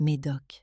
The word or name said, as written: Clipping